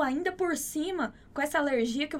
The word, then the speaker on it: alergia: Muito cuidado com essa pronúncia dessa palavra, né? A gente não vai falar alergia, a gente não tem nenhum acento ali no ri. Então, a pronúncia dessa palavra é alergia, ok?